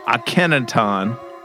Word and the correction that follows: Akhenaten: God, I could have butchered that name. They have a they have a project together Akhenaten. called Akhenaten. There we go.